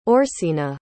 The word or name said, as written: orsina